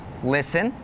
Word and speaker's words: listen: Some examples with a silent T are the words listen, the word castle, and the word Christmas.